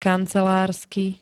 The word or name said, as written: kancelársky